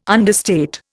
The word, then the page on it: understate: Indian